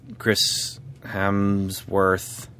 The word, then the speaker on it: Chris Hemsworth: Also, again, I mean, this guy, Chris Hemsworth.